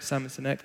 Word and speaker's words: Sinek: Simon Sinek, I think so.